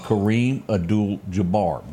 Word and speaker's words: Kareem Abdul-Jabbar: The next was this guy named Kareem Abdul-Jabbar.